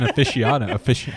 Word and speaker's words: aficionado: To say you're bourbon aficionado. How do you say that word? – Aficionado. – Aficionado. – Yeah.